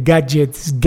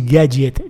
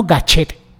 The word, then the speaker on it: gadget: Y hoy quiero hablaros de gadgets, pronunciado así, gadget, no gachet, gadget, como si fueras argentino.